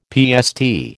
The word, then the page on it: PST: American English